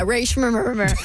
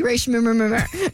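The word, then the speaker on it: Rae Sremmurd: Yeah, Ray Shrimmer. – Yeah, go with that. That's better. – It's spelled S-R-E-M-M-U-R-D, Ray Shrimmer.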